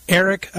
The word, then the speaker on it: Arek: And my second guest will be, uh, and no easier in, uh, in pronunciation here, Arek uh, Sokol, uh, founder. I know it sounds like Eric, but that's not how it's spelled.